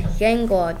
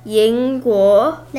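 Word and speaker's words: Yingguo (英国): Yingguo. Did I say that wrong? – No, you didn't say it wrong. But just keep practicing that. Yingguo.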